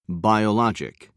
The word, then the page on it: biologic: British English